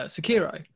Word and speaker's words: Sekiro: I've wanted to play uh, Sekiro. Um, I probably said that wrong, but no, no uh, that's fine. Oh, is it right? Ah.